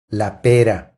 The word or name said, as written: La pera